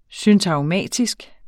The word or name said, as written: syntagmatisk